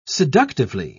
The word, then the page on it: seductively: British English